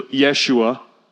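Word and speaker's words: Yeshua (ישוע): You're going to call his name Jesus, which they would have pronounced that more closely to Yeshua, which Yeshua literally means what?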